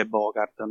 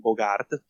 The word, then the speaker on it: Bogart: Perché è vero che eh, Humphrey Bogart, e la pronuncia è Bogart, non Bogart, ti prego.